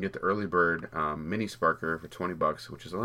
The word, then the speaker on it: Sparkr: It's Sparkr. It's spelled S-P-A-R-K-R.